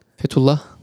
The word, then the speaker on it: Fethullah: First name F E T H U L L A H. And how do you pronounce that? Fethullah.